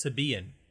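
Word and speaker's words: Sybian: The accent is on the second syllable. It's Sibian.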